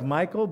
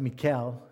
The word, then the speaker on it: Michal: As the ark of the Lord was entering the city of David, now it looks like Michael, but it's actually pronounced Michal. Michal, the daughter of Saul, watched from a window.